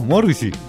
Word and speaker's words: Morrissey: ¿Morrissey? – Así se pronuncia.